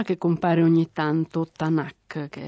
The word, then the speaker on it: Tanakh: Quindi si mette poi in mezzo una vocale per rendere pronunciabili le tre consonanti, e gli ebrei chiamano la Bibbia Tanakh, cioè la Bibbia è Torah, Nevi'im, Ketuvim, legge, profeti e scritti santi.